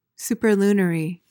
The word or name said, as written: superlunary